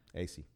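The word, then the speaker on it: Asay: Matt Assay is gone. Am I saying his name right? – AC. – AC.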